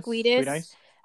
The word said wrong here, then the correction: Giudice: No, actually, I'm sorry. It's Giudice. Not even kidding. I literally looked that up.